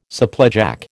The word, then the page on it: supplejack: American English